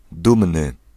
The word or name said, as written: fier